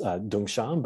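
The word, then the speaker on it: Dongshan: The latter character, Soto, the To is in Chinese way pronounced Dungshan, but in Japanese way we say Tozan.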